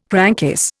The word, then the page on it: crankcase: Indian